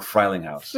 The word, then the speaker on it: Frielinghaus: So on today's episode of the Mindful Money Podcast, I'm chatting with, and I should have asked you how to say this before we started, Nikki Gajoo-Frielinghaus or Frielinghaus? – Frielinghaus. – I got it right the first time. Frielinghaus, very German.